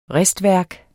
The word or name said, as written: ristværk